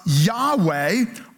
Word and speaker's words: Yahweh: And so it's often pronounced Yahweh or Jehovah.